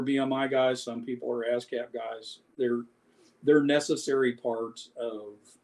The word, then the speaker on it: ASCAP: And even though you're part of Abscam, are you not? Did I say it correctly? ASCAP.